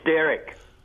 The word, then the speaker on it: Derrick: Yes, Des, what can I do for you? – Derrick, John. – Beg your pardon? – It's Derrick.